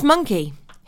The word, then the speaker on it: Mynci: Gorky's Psychotic Monkey. Not Minchy, as we've been saying. It's interesting, though, because it's actually spelled M-Y-N-C-I. But it's Monkey.